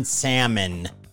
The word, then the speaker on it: salmon: Just let my wife know that the L is silent in salmon.